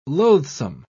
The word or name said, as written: loathsome